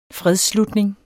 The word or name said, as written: fredsslutning